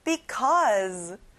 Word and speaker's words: because: 무엇무엇 때문에 뭐뭐 해서 because 끝에 z 발음이 나요.